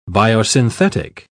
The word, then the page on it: biosynthetic: British English